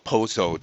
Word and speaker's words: pozo: So, for example, if you're upper middle class and you live in a residential tower with its own pozo, a well is called a pozo down here, P-O-Z-O, rhymes with bozo.